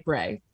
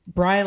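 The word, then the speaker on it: Brey: It looks like Bray because it's B-R-E-Y, which looks like it should rhyme with gray. So what I always say is it's Bry like try, don't ask me why.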